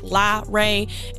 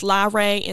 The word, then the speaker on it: LaRae: To be real with you, I didn't even really give it much thought, but the reason why is because the way I say my name is Miss LaRae, and I wanted there to be some significance in the separation between La and Ray. And then, you know, there's just one between my name and then podcast. No really significance as to why, just because I feel like, you know, it separates how I want my name to be pronounced, Miss LaRae, and then just to separate it out from that name to podcast.